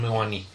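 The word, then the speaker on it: Mounié: Against Crystal Palace, Joe Ward with an old goal, and Mooney, Mwani? How do you say this? – Stephen Mwani.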